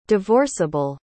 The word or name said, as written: divorceable